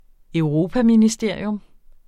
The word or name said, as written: europaministerium